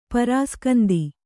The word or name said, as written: ಪರಾಸ್ಕಂದಿ